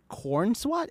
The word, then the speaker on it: Corenswet: So Superman and Lois, they casted David Cornswat. Is that how you say his name? – Yep.